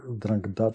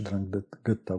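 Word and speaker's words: 'Ndrangheta: Zmierzam ku temu, że boss Cosa Nostry, czyli sycylijskie odmiany, trochę inne niż Drangheta czy Drangheta, bo w zależności od wymowy.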